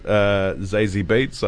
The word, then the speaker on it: Zazie Beetz: Uh, um, yeah, I really liked act- the actress Zazie Beetz.